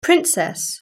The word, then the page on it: princess: British English